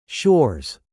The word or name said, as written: shores